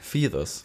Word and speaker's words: Feathers: So wie Mischung aus Fear und Father. Feathers.